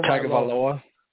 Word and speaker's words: Tagovailoa: I go Tagovailoa.